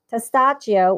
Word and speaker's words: Testaccio: Okay, so you uh, so uh, Cyril directed Testaccio, which is I'll spell it for everyone: T E S T, like test Accio, A C C I O. So testaccio. It's Italian. That mean it's Italian word for what is it again?